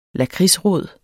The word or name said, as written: lakridsrod